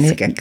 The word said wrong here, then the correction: Biskek: neve Biskek. Biskek, Biskek így igen.